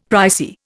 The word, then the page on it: pricey: Indian